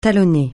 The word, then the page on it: talonner: francais